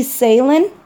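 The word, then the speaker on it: Ceylan: It's Lucy Salen.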